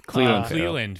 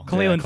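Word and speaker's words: Clelin: And then Clinton Farrell, I don't even know if I pronounced that right. – Cleland Farrell. – Cleland Farrell. I barely remembered his first name.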